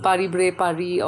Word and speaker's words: Paris-Brest-Paris: So the, even the breast is not pronounced fully. It's Paris-Bray-Paris or just PBP is fine.